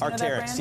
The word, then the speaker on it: Arc'teryx: Arc'teryx, is that how you pronounce it? Arc'teryx? – Arc'teryx, yeah.